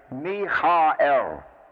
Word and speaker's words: Michael: This is pronounced actually as Michael.